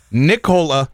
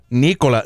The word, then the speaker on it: Nikola: Nikola Jokic, not Nikola. It's not the cough brand, the cough syrup.